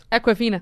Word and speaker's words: Awkwafina: Awkwafina.